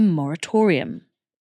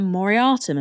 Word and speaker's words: moratorium: If such a pause cannot be enacted quickly, government should step in and institute a moratorium. It's a good job I said that right, because I always used to call it a moriatum and be laughed at extensively.